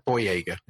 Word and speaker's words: Boyega: That's how you pronounce his name, is it? – Boyega, isn't it? Yes.